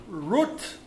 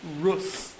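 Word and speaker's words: Rut (רות): We're going to talk about the story of Ruth, or in Hebrew, Rut, or in an Ashkenazic pronunciation, Rus.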